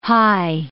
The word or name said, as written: hi